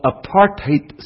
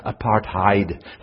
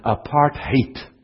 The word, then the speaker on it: apartheid: That's the proper way to pronounce the word, although in the United States most people say apartheid, thinking it's German. It's not German, it's Afrikaans. But it's if you hear it properly, apartheid.